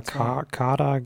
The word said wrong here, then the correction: Kadir: G. – Kadir. Kadir. – Kadir, ah. Kadir. Ja, ich kann es nicht aussprechen.